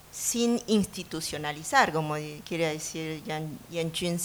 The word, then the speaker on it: Yan Jun: Pero sí lo pudimos hacer, pudimos interpretar la idea que tiene Yan Jun, así pronunciando en chino,